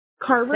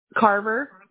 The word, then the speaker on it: Carver: Carver. – Say that. Harbor? – Carver. Yeah. – Spell it. Is it C-A-R-V? – Mine. Yes. – C-A-R-V-E-R.